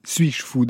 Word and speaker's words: S8JFOU: Un mot sur ce pseudo que vous êtes choisi, Suis-je fou D'abord, il faudra bien dire comment ça s'écrit et comment on le prononce. Ça s'écrit avec un S, puis il y a le chiffre 8, puis il y a la consonne J et enfin fou quand on le regarde,